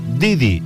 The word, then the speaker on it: Deirdre: con su hippie favorita que se llama Deirdre, pero se pronuncia Didi o como mejor les parezca, han vuelto a Jerez para quedarse y darse chapuzones de felicidad en los Caños de Meca.